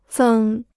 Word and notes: 增: zēng

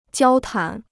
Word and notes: 交谈: jiāo tán

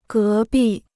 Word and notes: 隔壁: gé bì

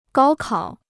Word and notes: 高考: gāo kǎo

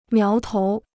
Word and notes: 苗头: miáo tou

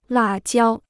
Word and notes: 辣椒: là jiāo

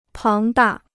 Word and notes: 庞大: páng dà

